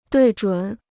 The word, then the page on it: 对准: duì zhǔn